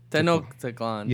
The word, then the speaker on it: Tenochtitlan: Tenochtitlan.